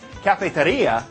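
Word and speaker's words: cafetería: You might be thinking about the one at school, but in Spanish it's pronounced cafeteria, and in that language it means coffee house.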